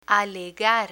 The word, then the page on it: alegar: spanish